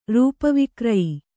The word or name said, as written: ರೂಪವಿಕ್ರಯಿ